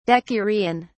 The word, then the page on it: decurion: British English